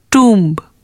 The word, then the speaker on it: tomb: In the words that you are going to hear now, the letter B is silent when it appears with M, like womb, tomb, bomb, climb, comb.